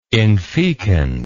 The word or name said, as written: infecund